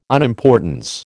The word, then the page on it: unimportance: American English